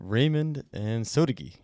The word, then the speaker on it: Raymond Ansotegui: Raymond Ansotequi.